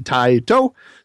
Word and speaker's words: Taito: According to the old PlayStation game, when you would turn it on, it would say Taito. So that's the pronunciation I go with.